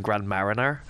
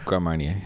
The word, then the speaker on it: Grand Marnier: Because I think I keep saying Grand Mariner. – Grand Marnier. – Yeah, I'm saying it right.